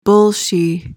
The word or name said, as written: bolshie